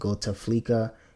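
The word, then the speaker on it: Gotaflika: So I strongly suggest that you all go on YouTube, you all check him out on Spotify, just Google his name, it's G-O-T-A-F-L-I-K-A, pronounced Gota Flicka.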